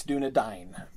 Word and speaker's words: Dúnedain: A-I-N, right. – Or the Edain in the first, in the Silmarillion, right? It's Ein, that's not Dúnedain, it's Dúnedain. Because that's the diphthong, and he's explicitly said, not only has he said it's pronounced like rye, but he goes out of his way to emphasize it's not pronounced A.